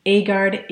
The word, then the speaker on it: Agard: Knight spelt with a K-N-I-G-H-T, Agard, A-G-A-R-D.